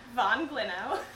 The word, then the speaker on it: von Glinow: This is Kiki von Glinow.